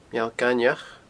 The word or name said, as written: Meall Gainmheach